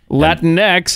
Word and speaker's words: Latinx: Latinx is a gender neutral there's a word I can't pronounce, sometimes used instead of Latino. Look at that, man. You nailed it.